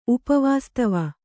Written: ಉಪವಾಸ್ತವ

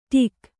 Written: ಡೆಕ್ಕು

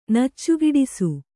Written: ನಚ್ಚುಗಿಡಿಸು

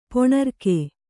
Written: ಪೊಣರ್ಕೆ

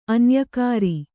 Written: ಅನ್ಯಕಾರಿ

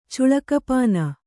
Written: ಚುಳಕಪಾನ

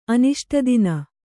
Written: ಅನಿಷ್ಟದಿನ

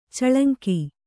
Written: ಚೆಲಂಕು

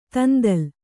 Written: ದಂದಳ

